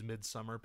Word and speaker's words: Midsommar: It's spelled all funky, but it's Midsommar, basically.